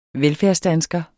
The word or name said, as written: velfærdsdansker